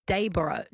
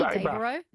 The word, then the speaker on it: Dayboro: Daybra. It's spelled O-R-O through me. – It is. – Yeah. It should be Daybra, B-R-A-H. – Yeah. Everyone south of the border calls it Dayboro.